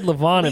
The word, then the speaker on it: Levon: I just like how you say Levon. – I really want to put emphasis on the E because I think it's, I said Levon at first and you're like, no, it's Levon. I'm like, okay, Levon.